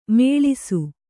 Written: ಮುಳಿಸು

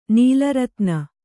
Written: ನೀಲರತ್ನ

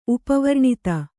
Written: ಉಪವರ್ಣಿತ

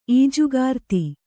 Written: ಈಜುಗಾರ್ತಿ

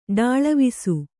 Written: ಢಾಳವಿಸು

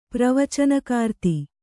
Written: ಪ್ರವಚನಕಾರ್ತಿ

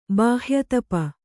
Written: ಬಾಹ್ಯತಪ

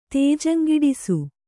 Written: ತೇಜಂಗಿಡಿಸು

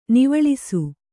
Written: ನೀವಾಳಿಸು

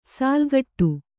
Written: ಸೆಲೆಕೊಡು

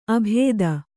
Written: ಅಭೇದ

